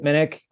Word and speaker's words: Minnich: My Twitter handle is at Chip Minnick. Last name is spelled M-I-N-N-I-C-H.